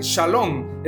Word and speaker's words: shalom: Shalom hebreo, pronúncese shalom, es una palabra hebrea que significa paz o bienestar.